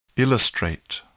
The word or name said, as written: illustrate